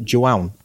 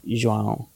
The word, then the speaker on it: João: It's spelled J-O-A-O. – Even I can't say that. João. – João. – There's no N. – It's no, there's no N on it, but Joao.